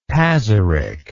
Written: Pazyryk

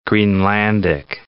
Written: Greenlandic

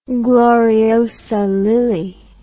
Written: gloriosa lily

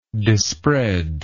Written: dispreader

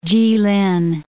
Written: Jilin

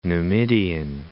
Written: Numidian

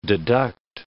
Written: deduct

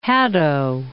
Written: Hadow

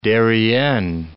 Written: Isthmus of Darien

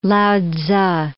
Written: Lao-tzu

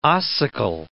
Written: ossicle

